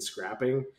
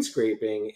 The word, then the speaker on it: scraping: As an aside, I had a colleague years ago who used to mispronounce it screen scrapping.